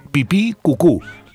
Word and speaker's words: pipicucu: El Facebook es pipicucu, así nomás, pipí con acento en la segunda I, cucú con acento en la segunda U, pipí cucú.